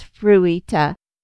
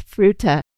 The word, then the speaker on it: Fruita: I thought it was Fruita. I think I wanted to pronounce it like you would in Japanese. Not that I speak Japanese either. And the lady was like, it's Fruita. And I was like, oh, well, I guess that makes sense.